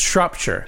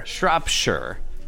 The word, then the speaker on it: Shropshire: It's pronounced Shropshire. – Shropshire.